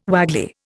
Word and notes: waggly: Indian

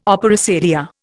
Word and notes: opera seria: Indian

